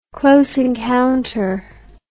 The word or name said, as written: close encounter